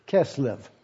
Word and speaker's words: Kislev: That's pronounced K-I-S-S-L-E-U or L-E-V, Kislev, in the 20th year.